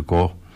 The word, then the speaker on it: Koog: En onder de bezielende leidingen van de SOS-band uit de Koog... zeg ik dat goed? Ik zeg het uit mijn hoofd. Ja, uit de Koog.